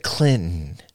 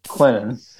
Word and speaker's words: Clinton: Clinton. – I did not. The listeners will be able to back me up. I'll let them be the judge. – You did not pronounce the T. – Clinton.